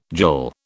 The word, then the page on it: jill: British English